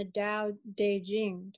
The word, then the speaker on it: Tao Te Ching: And Nicole's completing her book on the Tao Te Ching.